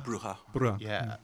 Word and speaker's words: bruja: I definitely read, how do you say witch? Is it Bruja? – Bruja. – Yeah.